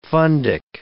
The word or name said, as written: fundic